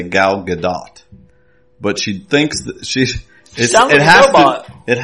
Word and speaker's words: Gal Gadot: Like, just say Gadot, but there should be, like, the ah should last longer, whatever. She was saying Gal Gadot. So, that's how it's supposed to be pronounced, supposedly.